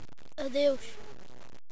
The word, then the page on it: adeus: portugal